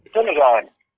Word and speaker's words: Cinedigm: Cinedigm? I can't even pronounce. What's that word? – Cinedigm.